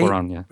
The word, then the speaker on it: Cuarón: It's Cuarón, right? Is that how you say his name? – Alfonso Cuarón, The way he shoots this movie is spellbinding.